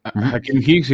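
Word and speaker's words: Akiem Hicks: Seahawks y a Hakim Hicks, ah, si lo dije bien. Sí, Hakim Hicks y, Fuller.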